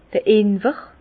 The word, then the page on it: d'r Inwer: Alsatian; Bas-Rhin; Herrlisheim